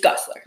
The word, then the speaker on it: Gosselaar: It's spelled weird. – Yeah. It's just Gosselaar.